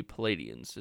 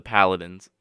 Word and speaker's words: Paladins: I think I'm going to have to go with the Palladians. Is that how you pronounce it? – I love that. I have heard to go to Furman. It is the Paladins.